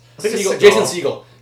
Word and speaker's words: Segel: I forget how it's pronounced. – Jason Segel. Because it's Seagal is the fat karate guy.